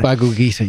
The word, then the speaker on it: Bhagavad Gita: And there was one thing that that teaching, you were saying the Bhagavad Gita, how do you pronounce that? – Bhagavad Gita, yeah.